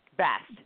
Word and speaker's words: Bast: How do you pronounce that? Bast. – Bast.